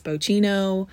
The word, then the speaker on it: Bocchino: It's spelled B-O-C-C-H-I-N-O. People think it's Bocchino, Cappuccino, anything that you can think of. So think of a hair bow, a key, and no. And that is how you say it, Bocchino.